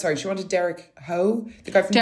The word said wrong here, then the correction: Derek Hough: Derek Hough.